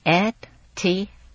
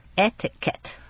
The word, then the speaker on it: etiquette: Etiquette. 那个 Q-U 啊是发 K 的音，而不是发 Q 的音。 Etiquette.